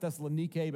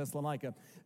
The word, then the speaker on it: Thessalonike: It's technically pronounced Thessalonike, but we can't break it, so we're going to Thessalonica.